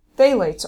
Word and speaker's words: phthalates: Phthalates are big. These are gender benders. – PHTH. – Yes. – Phthalates. – Phthalates. So the PH right. is yeah. actually not pronounced at all.